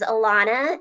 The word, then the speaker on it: Alonna: My email address is Alana, and that is spelled, well, it's on the screen, but A-L-O-N-N-A.